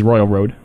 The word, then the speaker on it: Royal Road: That's what it is, Royal Road. – Yeah, he was saying it wrong before.